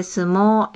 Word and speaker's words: sumo: 相撲。¿Cómo se dice sumo en español? – Se dice sumo.